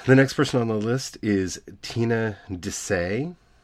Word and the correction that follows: Desai: Um, I know I mispronounced that name. Um, D E S I A. I'm sorry, AI. So Desai, Desai.